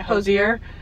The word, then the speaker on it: Hozier: Hozier. – I always say Hozier because I feel like it's like French or some shit. But yeah, Hozier.